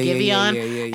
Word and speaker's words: Giveon: Yeah, Giveon.